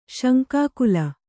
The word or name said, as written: ಶಂಕಾಕುಲ